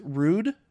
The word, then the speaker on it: Rhude: I think it's the brand is pronounced Rude, R-H-U-D-E.